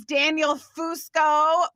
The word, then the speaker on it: Daniel Fusco: And my guest name is Daniel Fusco.